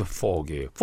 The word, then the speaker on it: fogey: 아, young foggy, old fog이. fog이, fog이. 다시 한 번 더 스펠링은요? F-O-G-E-Y.